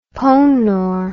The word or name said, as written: ponor